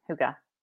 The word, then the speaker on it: Hygge: Am I pronouncing it correctly? – Somebody else said it. Yeah, that it was pronounced like that. Hygge. – Hygge. It's like hookah.